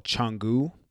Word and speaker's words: Canggu: We did spend the first leg of our trip, I think about 5 or 6 days, in a place called Canggu. And all their C's are spelled or said with C-H. So it's C-A-N-G-G-U, but it's pronounced Canggu.